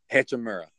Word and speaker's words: Hachimura: How do you pronounce his last name? – Hachimura.